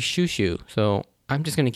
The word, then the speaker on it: Chouchou: So, I know in the romanization spelling and in the subtitles, it's spelled C-H-O-U-C-H-O-U, and so it's spelled like it's supposed to be sound choo-choo, and I don't know if that's actually how it's pronounced in the English dub, but in Japanese, it's pronounced Shushu, like S-H-U-S-H-U.